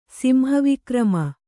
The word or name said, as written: ಸಿಂಹವಿಕ್ರಮ